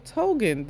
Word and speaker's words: Tongan: She was Hawaiian descent and also Tongan descent.